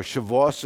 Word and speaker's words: Shavuot: So Pentecost is also known as the Feast of Weeks, or Shavuot, if I say it right, or Shavuos, it can be said.